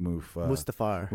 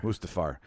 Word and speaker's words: Mustafar: And we were on, I always mispronounce it, Darth Vader's planet, Mustafar. – Mustafar.